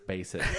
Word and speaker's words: Bassett: Bass. It's pronounced Bassett.